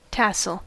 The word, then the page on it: gland: [glɑ̃]; /ɡlɑ̃/; France